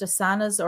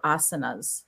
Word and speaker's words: asana: So it's combining the posture with the breath, with the, is it pronounced asanas or asanas? – Asana. – Asana. Asana. See, so that's important information as I'm going.